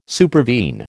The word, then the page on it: supervene: American English